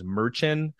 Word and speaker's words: Merchan: I believe his name is Merchan, and I may be mispronouncing that. It's basically Merchant without the T at the end.